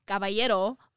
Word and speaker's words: Caballero: Caballero, the way that it's supposed to be pronounced.